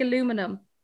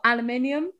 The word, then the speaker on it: aluminium: Like aluminum. You mean, aluminium? etc. Then, here, there it is. He cupped his ears in pleasure. That extra syllable. Aluminium.